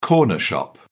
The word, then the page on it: corner shop: British English